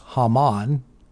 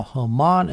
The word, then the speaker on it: Haman: The Hebrew for Haman, and of course Haman is how I'm pronouncing it in English. The Hebrew is Haman.